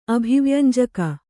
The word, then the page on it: ಅಭಿವ್ಯಂಜಕ: abhivyañjaka